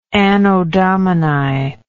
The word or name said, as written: anno domini